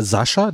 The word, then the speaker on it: Sacha: Aber Sascha, da geschrieben steht Sacha. – Ja, ist eine Variation.